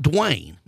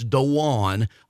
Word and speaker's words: Daune: Now, this is, it's not Dwayne. A lot of people call it, it's Dawn, although it's spelled D-A-U-N-E.